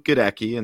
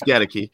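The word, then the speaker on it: Goedeke: I was pronouncing it Luke Getteke, and then I heard him. – We all were. – It's Getteke. But, you know, I don't think he was perceived to be, you know, a second round pick, or at least at that spot.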